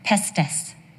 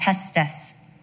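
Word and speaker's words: pistis: The Greek word for faith is the word pestis. That's how it's pronounced, pestis. It is the substance of what we believe, but it is also the conviction and investment in what we believe.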